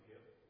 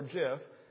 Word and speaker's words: gif: Yeah, Gideon and I have an ongoing debate back and forth if it should be pronounced gif or jif. Correct people say gif.